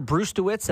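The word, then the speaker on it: Brzustewicz: Hunter Brustewitz, and I'm certain I'm not saying that right. – No, it sounds okay.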